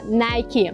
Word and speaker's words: Nike: Если в голове прозвучало Nike, то ставь лайк. Но на самом деле название этого бренда произносится совершенно не так. Название происходит от имени греческой богини победы Ники и произносится Nike.